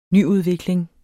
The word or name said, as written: nyudvikling